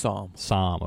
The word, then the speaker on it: Somme: the Somme, is that how it's pronounced? – Somme, okay.